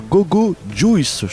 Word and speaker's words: Gogo Juice: Bienvenue à toutes et tous dans ce hors-série numéro 7 de la nouveauté rock française sur SLS consacrée à Gogo Juice. Je prononce bien ? – Tu prononces très très bien.